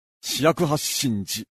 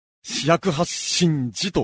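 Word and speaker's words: 四百八十寺: 480と書いて、四百八神寺。まあ、標則の関係で中国語の発音の関係で、十を読みにくいということで、ここでは神と読むことになっています。それに従って、日本語の読みでも、四百八神寺と読む習慣になっています。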